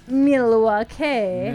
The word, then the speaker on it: Milwaukee: It's pronounced Milwaukee.